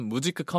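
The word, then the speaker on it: Musik: 무직회사는 무직크 컴퍼니라는 이름의 네. – 이름을 이제 한글로 무직회사라고 한 거였어요. – 네. – 그래서 무직크는 이제 독일어로 뮤직의 발음이고요.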